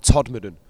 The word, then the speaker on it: Todmorden: People in the UK can't even pronounce where it is. It's just called Todmorden.